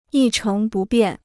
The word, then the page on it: 一成不变: yī chéng bù biàn